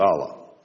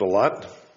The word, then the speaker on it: Allah: It means one who submits, one who follows the religion of Islam, one who submits to Allah. Okay. Now, Allah, or Allah, it's pronounced a lot, is Islam's name for the one true God.